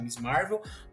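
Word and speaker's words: Ms. Marvel: Que, é, que se pronuncia Miss Marvel,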